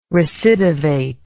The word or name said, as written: recidivate